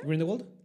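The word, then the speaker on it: Grindelwald: No, que se supone que el Green ¿Cómo se pronuncia? – Greenwald Ajá, ese verga, así.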